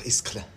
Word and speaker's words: Ischgl: Ischgl.